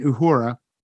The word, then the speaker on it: Uhura: I thought you were correcting my pronunciation of Uhura. – Oh, no. It was very, very, very well done.